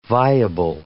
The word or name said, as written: viable